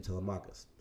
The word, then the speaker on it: Telemachus: I pronounce his name Telemachus.